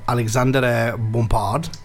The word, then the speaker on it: Alexandre Bompard: Now, announcing the planned tie-up with Tesco, the Carrefour Group uh, chief executive Alexandre Bompard well pronounced.